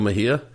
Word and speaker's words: Mejia: Is that how you say his name? Adalberto Mejia? – So, yeah, Adalberto Mejia.